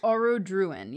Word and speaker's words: Orodruin: Is it Orodruin? – Orodruin, yes.